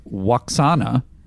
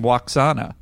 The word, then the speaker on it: Lwaxana: I thought the person I realized in this episode, is her name is Lwaxana, is how she herself pronounces it, unless I'm mistaken. In this episode, as the computer, she pronounces her name Lwaxana.